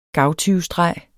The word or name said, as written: gavtyvestreg